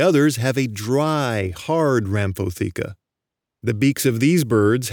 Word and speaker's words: rhamphotheca: The outermost layer of the beak, the one made of keratin, is called the rhamphotheca. Rhamphotheca, and that's spelled R-H-A-M-P-H-O-T-H-E-C-A. Rhamphotheca.